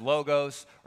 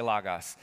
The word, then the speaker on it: logos: You'll hear it pronounced logos or logos. As far as I can tell, all three are correct, depending on who you're sitting in front of. I say logos.